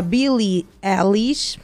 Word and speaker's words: Billie Eilish: Melhor canção No Time to Die, da cantora Billie Eilish. Não sei se está correta a pronúncia, é isso, Jacob? É isso mesmo.